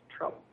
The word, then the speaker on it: Trubel: It looks like it is her name, and it's spelled T-R-U-B-E-L. Her name is Trouble.